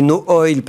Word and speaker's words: Noil: N-O-I-L. Moi, je bien prononcer No Oil parce que ça me semble évident que ça se prononce comme ça.